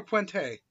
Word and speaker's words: Puente: Puente, is it Puente or Puente? – Puente.